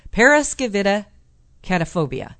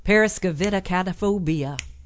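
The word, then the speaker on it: paraskevidekatriaphobia: Periskevidacatrophia. No. Periskevidacataphobia. Periskevidacataphobia.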